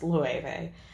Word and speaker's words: Loewe: The brand, Loewe, is not pronounced low, you guys. It's pronounced Loewe.